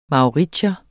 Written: mauritier

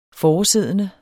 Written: foresiddende

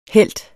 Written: helt

